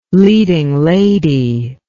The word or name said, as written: leading lady